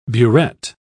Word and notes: buret: British English

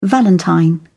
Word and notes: valentine: British English

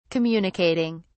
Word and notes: communicating: British English

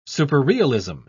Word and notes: superrealism: British English

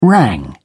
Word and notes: rang: British English

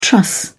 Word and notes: truss: British English

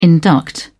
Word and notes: induct: British English

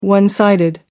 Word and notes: one-sided: American English

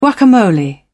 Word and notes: guacamole: British English

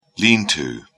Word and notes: lean-to: British English

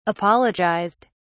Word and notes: apologized: British English

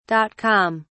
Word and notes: dot com: British English

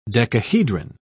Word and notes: decahedron: British English

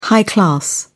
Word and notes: high-class: British English

